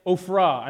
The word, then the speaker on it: Ophrah: It says, the angel of the Lord came and he sat under the oak that was in Ophrah. I know it looks like Oprah, but it's pronounced a little bit differently. So not the you get a car, different person. It's actually a land.